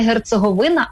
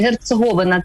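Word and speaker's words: Герцеговина: От ну, ну ви так цікаво робите наголос не герцоговина, а герцоговина.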